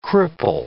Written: cripple

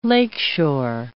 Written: lakeshore

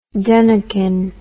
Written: dunnakin

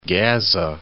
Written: Ghazze

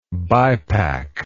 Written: bipack

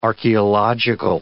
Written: archaeological

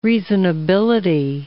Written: reasonability

